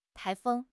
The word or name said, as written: 台风